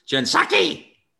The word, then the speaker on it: Jen Psaki: Jen Psaki.